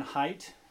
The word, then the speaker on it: Haidt: So my title is, well, I don't know if you, this is still on Jonathan Haidt. That's how you pronounce his name, H-I-D-T.